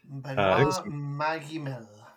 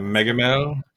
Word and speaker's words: Benoît Magimel: Benoît Magimel. – Yeah, Magimel.